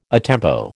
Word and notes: a tempo: American English

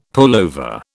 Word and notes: pullover: British English

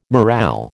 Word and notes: morale: American English

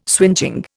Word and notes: swingeing: Indian

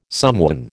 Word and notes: somewhen: American English

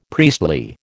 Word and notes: priestly: American English

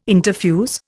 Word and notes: interfuse: Indian

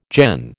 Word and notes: gen: American English

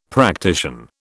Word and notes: practician: British English

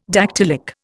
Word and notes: dactylic: Indian

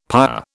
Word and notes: père: British English

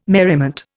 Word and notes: merriment: Indian

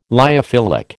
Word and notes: lyophilic: American English

